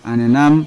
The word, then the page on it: anenam: Yawa